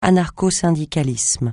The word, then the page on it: anarcho-syndicalisme: francais